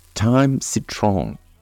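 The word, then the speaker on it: thym citronné: Lemon thyme, or citrus thyme as it is also known, is pronounced thyme citron in French, and spelt thyme, T-H-Y-M, citron, C-I-T-R-O-N-N-E.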